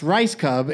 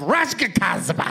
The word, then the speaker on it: Rajskub: And it's pronounced rice cub instead of Rajskazba.